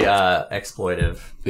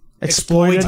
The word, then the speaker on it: exploitative: No, it's both. – Exploitative? – Are you sure? – Yeah. – It's both. It is both.